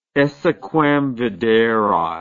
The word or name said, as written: esse quam videri